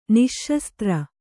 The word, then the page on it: ನಿಃಶಸ್ತ್ರ: Kannada